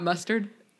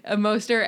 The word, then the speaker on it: Mostert: Mostert? I thought it was Mostert. – Not mustard. – He's the Raheem with the Mostert. – Yeah, Mostert.